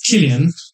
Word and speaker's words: Cillian: It's pronounced Killian.